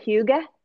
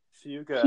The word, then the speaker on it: hygge: Have you ever heard of the word hygge? – Hygge.